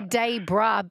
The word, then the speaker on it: Dayboro: It's spelled O-R-O through me. – It is. – Yeah. It should be Debra, B-R-A-H. – Yeah. Everyone south of the border calls it Debra, but yeah, fair enough.